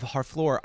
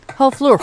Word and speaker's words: Harfleur: Just about your pronunciation of Harfleur, I think it was close. The only thing it was missing at the end was a just because you don't Harfleur. Yes, there we go.